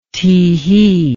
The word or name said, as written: tehee